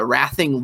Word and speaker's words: Racing: Uh, Rathing is like the Spanish word racing in those club names. is it's pronounced because Spanish people, you know, like say Barcelona, like Barcelona. You have to like pronounce your C's like a TH.